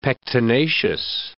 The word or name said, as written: pectinaceous